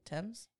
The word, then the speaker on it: Thames: Thames? – You did.